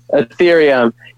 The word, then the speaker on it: Ethereum: Ethereum.